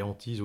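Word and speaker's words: HANT1S3: Alors, l'idée, c'est qu'en plus de, s'appeler Antise ou Inner, ça s'écrit absolument pas comme ça se prononce. donc, Antise, donc c'est H-A-N-T-1-S-3, c'est-à-dire qu'on remplace le I et le E par le chiffre 13, donc 1 et 3.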